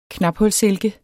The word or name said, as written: knaphulssilke